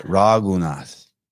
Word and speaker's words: Raghunath: Raghunath.